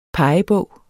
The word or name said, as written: pegebog